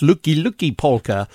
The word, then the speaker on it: Lucky Lucky Polka: And also, it says Lucky Lucky Polka, which apparently is pronounced Luki Luki Polka in Polish.